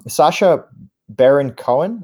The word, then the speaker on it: Sacha Baron Cohen: And it's happened in the previous films because, it's Sacha Baron Cohen.